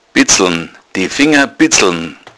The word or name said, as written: bitzeln